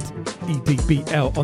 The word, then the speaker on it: EDBL: And EDBL on that remix. Shout out to EDBL. Edbl, as it's pronounced.